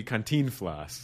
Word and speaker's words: Cantinflas: Or if I was really pronouncing it, would be Cantinflas.